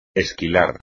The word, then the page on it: esquilar: espagnol